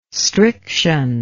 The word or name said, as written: striction